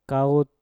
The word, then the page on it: kaut: Mpur